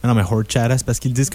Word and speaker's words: Horchata: Je prononce pas assez. Horchata! – Horchata. Horchata. Tu peux le dire ouais, comme ça, avec le petit accent et tout.